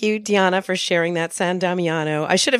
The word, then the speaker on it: San Damiano: It's actually Italian, and it's pronounced San Damiano. So that's San for Saint, and Damiano, which is Damien, which is the church where St. Francis heard God speaking to him from the crucifix.